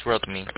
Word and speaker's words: Rodney: Yeah, my name is Rodney. – R-O-D-N-E-Y? – Yeah, R-O-D, M as in Michael, Y as in yellow.